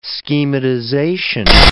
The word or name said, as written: schematization